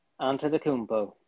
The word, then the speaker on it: Antetokounmpo: Antetokounmpo? – Antetokounmpo, yep.